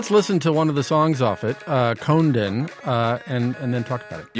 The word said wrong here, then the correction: Kondaine: You pronounce it Kondaine. – Kondaine. – Yeah, Kondaine.